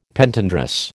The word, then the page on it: Pentandrous: American English